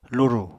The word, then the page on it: lurù: Apatani